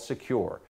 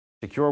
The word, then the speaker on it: Sekur: That's why you need a new cybersecurity application tool called Secure. It's spelled S-E-K-U-R. Secure is using proprietary encryption and offering secure instant messaging and email.